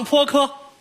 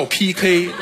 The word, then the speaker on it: PK: 嗯，咱们现场泼科。 – 现场干什么？ – 泼科。 – 傻子那叫 PK。